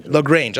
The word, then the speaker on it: LaGrange: LaGrange.